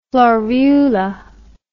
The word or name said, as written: florula